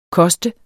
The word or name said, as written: koster